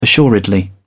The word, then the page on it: assuredly: British English